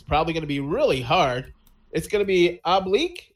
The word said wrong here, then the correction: Obelix: Is that how you say it, Christy? Oblique? – Obelix. – Obelix. – Obelix. – Obelix. It's French, goddamn it. I don't know French.